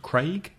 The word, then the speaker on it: Craig: And the vowel sound, A, my name, Craig, or say, is raise, levantar.